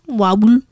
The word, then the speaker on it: Wawel: Number five, the ghostly gathering of kings at Wawel Castle. – At Wawel? – I think it's W. – Did you say Wawel? – Wawel. W-A-W-E-L.